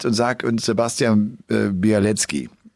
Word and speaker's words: Białecki: Aber auch andere sagen, nein, der wird Biawetski ausgesprochen. Also das L wohl nicht, sondern wie so ein W und dann halt Biawetski. Und äh,